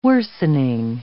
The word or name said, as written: worsening